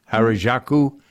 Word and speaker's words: Harajuku: How do you, it's spelled H A R A J U K U Harajuku.